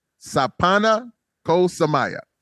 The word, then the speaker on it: Sapana Ko Samaya: Sapana Ko Samaya. Did I say that right? – Oh, that was pretty good. – That was pretty good, actually.